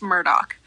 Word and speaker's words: Murdaugh: It is pronounced Murdoch.